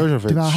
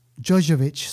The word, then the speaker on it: Jovovich: Jovovich. – How do you say it? – Yeah, Jovovich. Yeah. – Jovovich, sorry.